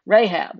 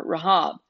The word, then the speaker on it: Rahab: We also recognize the name Rahab, or Rahab, however you might pronounce it.